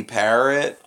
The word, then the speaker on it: Parrott: I've got Mora, Bergvine, Ali, and then maybe Parrott. – I don't know. How do you spell that? – Oh. – Well, it's spelled P-A-R-R-O-T-T. Perot? It's Parrot.